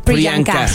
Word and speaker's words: Priyanka: Imagínense que el velo de Priyanka, ¿cómo se dice? – Priyanka.